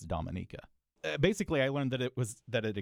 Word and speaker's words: Dominica: And I always wondered, is this just another name for the Dominican Republic? What's going on here? And so I always... of course, wanted to pronounce it Dominica, but I think it's Dominica. Is that right? – Wow.